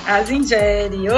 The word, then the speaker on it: Aza Njeri: Asa Nigéria.